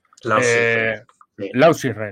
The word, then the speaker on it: Lausitzring: a Lausitzring, espero decirlo bien. – Lausitzring, eh, bueno, no lo he dicho mal entonces, en Alemania.